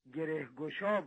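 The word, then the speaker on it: گره‌گشا: به نام گریگوشو، که من فکر میکنم اسم کتابی یا تلفظ درستش گرهگوشا بود، و به اصطلاح دعای میکردم برای باز شدن گره مشکلات مردم.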